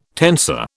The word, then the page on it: tensor: British English